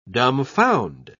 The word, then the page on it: dumfound: British English